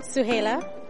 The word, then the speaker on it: Suhaila: Suhaila. – Suhaila, can you spell that for me? – S-U-H-A-I-L-A.